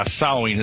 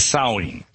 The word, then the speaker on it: Samhain: Is that the correct pronunciation? – Yeah, in the Celtic British Isles, yeah, Samhain. It's spelled Samhain, but we say it as Samhain.